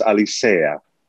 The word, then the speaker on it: Alicea: People don't pronounce the E. They pronounce it as an I, so they say Alicea. But it's an E, so it's Alicea.